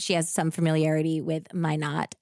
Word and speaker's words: Minot: And she was like, just so you know, it's spelled like why not Minot. – Okay. – Not um, pronounced, sorry, not, pronounced like Minot, why not? Minno. – Not Minno. Minno. North Dakota.